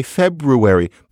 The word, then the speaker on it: February: So it's kind of analogous to reading pronunciation. We say February, but only because somebody decided that the language wasn't allowed to move along the way it had. And so feverer, feveral, we can't have that. We have to say February.